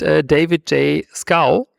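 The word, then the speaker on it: David J. Schow: Und David J. Scow, wie er ausgesprochen werden soll angeblich.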